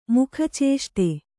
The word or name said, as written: ಮುಖಚೇಷ್ಟೆ